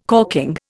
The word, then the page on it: corking: Indian